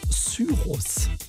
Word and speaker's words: Cyrus: Die schreibt man genauso wie Miley. Man spricht sie nur deutsch aus, und zwar Cyrus.